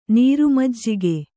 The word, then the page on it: ನೀರುಮಜ್ಜಿಗೆ: Kannada